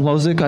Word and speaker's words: Hložek: Adam Hložek, I apologize for the pronunciation. – Oh, that's actually bang on.